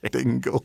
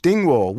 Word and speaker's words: Dingwall: I'm gonna let you have another go at your pronunciation of Dingwall. – Dingwall. Dingle. – The Dingle. You've got Emmerdale, aren't you? – Dingwall,